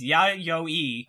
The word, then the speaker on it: Yayoi: The other thing I wanted to point out is how you said yayoi, and Tucker, our pronunciation guy, very explicitly sent us a message saying, do not pronounce it like that. It is yayoi, and I even wrote in bold next to the character's name how to say it.